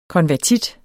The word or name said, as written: konvertit